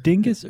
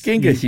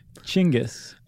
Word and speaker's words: Genghis: Do you pronounce it Dingus or Genghis or Chinggis? – Are you being serious? – I know it's not Dingus, but I've also seen it spelled in a way that would suggest you. – It's Genghis, you dingus. – You pronounce it Chinggis.